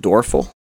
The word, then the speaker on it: Doerfel: Some people like Dorfel. We just pronounce it Dorful. That's how we've always pronounced it.